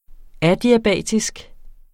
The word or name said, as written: adiabatisk